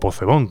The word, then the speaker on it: Pozzebón: Es la evocación que hacía en este caso desde tierras argentinas Vivi Possebón, o Possebón, como pronunciaríamos por aquí.